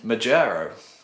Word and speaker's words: Majaro: Majaro. I hope I'm pronouncing that right. – I think you are. – M-A-J-A-R-O.